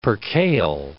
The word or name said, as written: percale